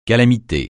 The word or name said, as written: calamité